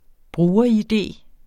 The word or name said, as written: bruger-id